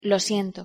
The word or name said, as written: lo siento